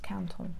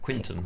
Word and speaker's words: Quinton: Quinton. It's pronounced Canton. – Yeah, but it's spelled Quinton.